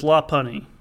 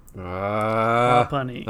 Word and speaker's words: Lopunny: This guy says Lopunny. Lopunny.